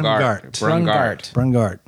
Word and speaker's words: Brungardt: Brungardt. – Okay. – Really exercise the G.